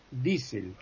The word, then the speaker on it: Diesel: Por ejemplo, la IE, IE, sí. En alemán es I. Por ejemplo, Kiel, es la ciudad portuaria, es Kiel. – Ya. Yeah. – Este señor Diesel es Diesel.